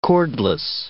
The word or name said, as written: cordless